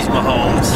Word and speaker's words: Mahomes: It's Mahomes.